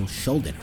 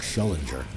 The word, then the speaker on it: Schuldiner: That's how you pronounce his name. Schuldiner. We should never pronounce his name right. We used to call him Chuck Schullinger. Like, we couldn't read him. When we were like teenagers, like, oh, the guy from Death, Chuck Schullinger.